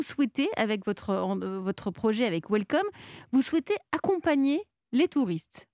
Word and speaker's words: WLCM: Vous êtes le fondateur de Welcome, mais ça s'écrit WLCM. C'est comme ça qu'on vous reconnaît. Mais ça se prononce Welcome, qui est une application qui regroupe toutes les informations touristiques d'une destination.